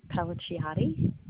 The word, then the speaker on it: Pellicciotti: Yes, Colleen Pellicciotti. – Can you spell that last name for me? – Sure, P-E-L-L-I-C-C-I-O-T-T-I. – O-T-T-I? – Yes.